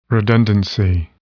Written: redundancy

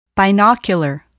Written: binocular

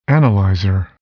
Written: analyzer

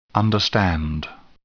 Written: understand

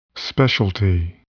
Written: specialty

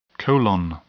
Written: colon

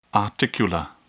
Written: articular

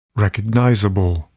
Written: recognizable

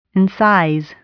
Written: incise

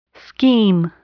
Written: scheme

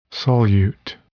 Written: solute

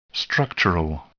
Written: structural

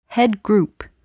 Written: headgroup

